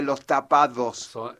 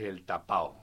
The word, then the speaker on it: tapao: No tapado, señora. No. Usted que vive ahí en el Tipal, no. – En el Monumento a Güemes. – El Monumento a Güemes, no. No. No se dice los tapados. – Es el tapao.